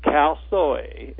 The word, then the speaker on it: Khao Soi: Khao Soi.